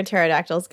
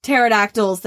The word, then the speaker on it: pterodactyl: Do you know why you can never hear pterodactyls go to the bathroom? – Gee, why? – Because the pee is silent.